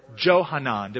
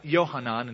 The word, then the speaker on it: Yohanan: While the people are surrounding him and rallying behind Gedaliah, a guy by the name of Yohanan, or Johanan, depending if you want to mispronounce it or pronounce it right, but Yohanan in Hebrew, comes up to him and informs Gedaliah of a conspiracy against him by a guy named Ishmael the Ammonite.